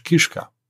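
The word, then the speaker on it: Kischka (кішка): Oder anderes Beispiel, Koschka, die Katze im russischen, heißt ukrainisch Kischka.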